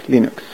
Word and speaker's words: Linux: Hello, this is Linus Torvalds and I pronounce Linux as Linux.